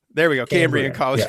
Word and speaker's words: Cambrian: Cambridge. – Cambridge. I'm saying it wrong. – Cambrian. – Cambrian. – C-A-M. Cambrian. There we go.